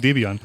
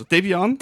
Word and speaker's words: Debian: Also Debian. – Debian oder im Englischen Debian. Ausgesprochen ist das Zusammenwürfeln der Namen des Gründers des Projektes. Ian Murdoch. Und seiner damaligen Frau Deborah.